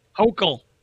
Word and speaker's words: Hochul: Well, how do you pronounce the new governor's last name? Is it Hochul? – Hochul.